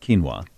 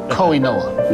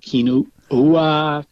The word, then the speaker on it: quinoa: Q-U-I-N-O-A. – That's, I do think I know how to pronounce that. Quinoa. – Kiana. – Nope. – Koinoa. – No. – Joaquin. – No. – Kokokonakwoa. – Nope. – Quinoa. Kinoa.